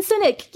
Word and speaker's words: Sinek: I'm not sure if I'm going to get his name right, Simon Sinek? – Oh yeah, Simon Sinek, yes.